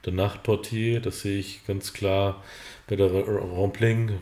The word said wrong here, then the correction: Rampling: wie spricht man es aus? Rampling, Rampling. Rampling, Charlotte Rampling.